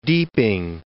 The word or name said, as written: Deeping